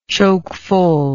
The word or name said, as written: chokefull